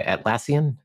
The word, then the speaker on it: Atlassian: That's made by Atlassian.